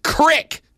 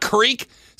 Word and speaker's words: creek: Texter says, first, it's pronounced crick, not creek.